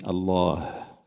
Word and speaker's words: Allah: Alors déjà, on escamote le H final qui est aspiré fortement. On dit Allah, on entend le à la fin.